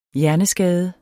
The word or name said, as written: hjerneskade